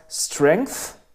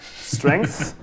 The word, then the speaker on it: Strength: Das Album heißt Strength, so wie Stärke, Strength. – Danke, dass du Band das für mich ausgesprochen hast. Ja. – Strength, TH, weißt du? TH. Und die Band kommt aus Portland, Oregon.